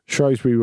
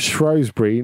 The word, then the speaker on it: Shrewsbury: Andy is not posh, but is correct in the pronunciation of Shrewsbury, not the phonetic Shrewsbury.